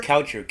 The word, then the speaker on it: Kaucher: Steven George Coucher is my full name. – Coucher? – Coucher. K-A-U-C-H-E-R. It's an old German name. – Okay. – And if I was in Germany, it would be pronounced Kaucher.